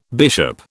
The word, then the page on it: bishop: British English